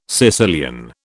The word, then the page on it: Sicilian: British English